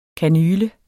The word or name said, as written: kanyle